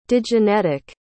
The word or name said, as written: digenetic